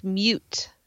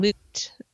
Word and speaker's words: moot: A lot of people will say, oh, the point's mute. And I'm like, oh, it's moot.